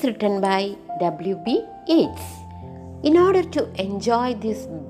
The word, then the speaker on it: Yeats: You have to pronounce it as Yeats.